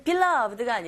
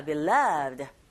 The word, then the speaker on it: beloved: 이 beloved 같은 경우에는 beloved가 아니라 beloved. 두 번째 위치에 강세가 있기 때문에 this와 쭉 한 덩어리처럼 this beloved 이렇게 들렸거든요.